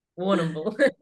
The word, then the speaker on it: Warrnambool: Warrnambool in Warrnambool.